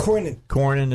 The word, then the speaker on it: Cornyn: Kanyon walked in. Senator Kanyon. – Wow. – Texas. He walked. – Cornyn. – Is that how he pronounces his name? – Yeah, he's an engineer.